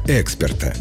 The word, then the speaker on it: эксперт: Нужно говорить «эксперт». Это единственно верный вариант, зафиксированный всеми словарями. Есть расхожее мнение, что «эксперт» с ударением на первый слог